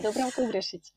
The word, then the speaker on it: Dubravka Ugrešić: I hope I'm pronouncing this correctly. – Yeah, Dubravka Ugrešić.